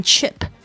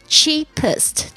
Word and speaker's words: cheapest: And book the cheapest, 这个 cheapest 有点难念哦，就是最便宜的 the cheapest, 这个 cheap, C-H-E-A-P, 这个 E-A 的声音不要发错了哦，要发长音的这个 E, 这个两颊比较感觉比较紧绷，然后嘴巴变成一直线的 E, 不要念成 chip 就会变成另外一个字了。 cheapest 就是最便宜的，加上定冠词的 cheapest ticket as early as possible.